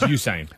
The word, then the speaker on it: Usain: But apparently, Usain Bolt's not Usain Bolt. – It's Usain.